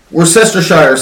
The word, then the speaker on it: Worcestershire: Worcestershire sauce."